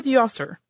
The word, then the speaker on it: Djoser: His name is spelled D-J-O-S-E-R, and you will hear it pronounced in a number of different ways. Us working with basically a dead language here, we have consulted many different sources, and we have gotten many different variations on this name from Djoser to Djoser to, you know, I mean, you could almost pick your pronunciation and someone out there in some field of expertise on it has used it. I think we're going to go with Djoser.